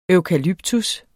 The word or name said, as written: eukalyptus